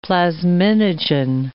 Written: plasminogen

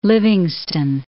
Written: Livingston

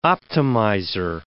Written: optimizer

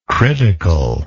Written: criticalness

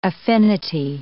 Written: affinity

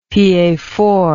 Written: piefort

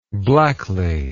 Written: blackly

